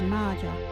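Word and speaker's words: maaja: local Wadjuri word is Marja.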